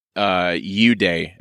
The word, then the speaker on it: Eudae: And walk me through, like in particular, you guys tout, U-Day, am I saying that right? – You are. – E-U-D-A-E, as a method of manufacturing clothes.